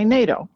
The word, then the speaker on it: NATO: We never say National Atlantic Treaty Organization. We say NATO.